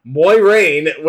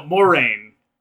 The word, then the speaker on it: Moiraine: I don't know anything other than Moiraine, which apparently I didn't know. – No, Moraine. Just Moraine. I'm pretty sure it does. You do not pronounce the I.